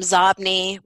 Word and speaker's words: Xobni: A classic one is a company whose name was spelled X-O-B-N-I. Impossible to pronounce. If I said the name Zobni, which is how it is pronounced, most people wouldn't know how to spell it.